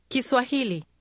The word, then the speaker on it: Kiswahili: Kiswahili.